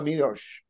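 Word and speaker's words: Miłosz: Is it Miloš? – Yeah. Yeah. Česlav Miloš.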